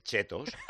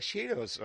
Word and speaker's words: Cheetos: Y dice el Chetos. – Sí, sí. – A ella le da un ataque de risa y dice, Cheetos.